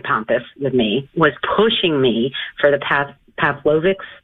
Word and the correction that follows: Paxlovid: however you pronounce that. – It's Paxlovid. I'm not against Paxlovid.